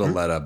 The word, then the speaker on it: Aleta: And it's, then it's spelled Aleta, but I might say Alita sometimes. I apologize. – I think you I think you said Alita last time. – Yeah, it's a it's Aleta.